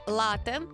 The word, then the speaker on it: лате: І кажуть, насправді, що з одного боку там треба ставити подвійний наголос, з другого боку кажуть, що це насправді «лате», тобто на перший склад треба обов'язково ставити наголос.